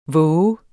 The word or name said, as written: vove